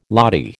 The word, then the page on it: lathi: American English